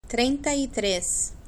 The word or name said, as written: trienta y tres